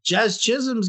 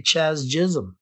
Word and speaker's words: Jazz Chisholm: And I actually said his name properly. I didn't say Chaz Chisholm.